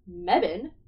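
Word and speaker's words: Mebane: But if you want to see them IRL and you feel like traveling to the quaint little town of Mebbin, it rhymes with heaven,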